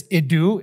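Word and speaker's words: idu (ἰδού): It's idu is what it's pronounced.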